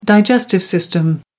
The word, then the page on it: digestive system: American English